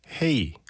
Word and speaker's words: Hei: 그리고 루카 씨 안녕하세요 세훈 씨 헤이 H-E-I 이건 핀란드 인사말입니다 하셔서 H-E-I가 핀란드어로 발음이 뭘까 찾아봤더니 헤이네요. 약간 허탈했어요. 헤이 나는 인사말과 발음이 똑같잖아요.